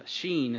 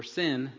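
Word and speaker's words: Shin: And this series is coming to a close as we enter this last, or the second to the last letter of Sheen is how it's pronounced, or Sin, depending on how it's used in the Hebrew.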